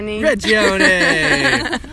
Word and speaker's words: Regione: Regione!